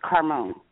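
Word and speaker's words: Karmon: It's K-A-R-M-O-N. It's pronounced Carmon.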